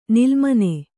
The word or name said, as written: ನಿಲ್ಲುಮನೆ